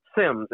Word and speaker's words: Sims: Marc, M-A-R-C, Sims, S-I-M-S.